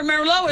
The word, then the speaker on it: Merlot: Yeah, remember Merlot is, and that T is silent the T on the end of Merlot.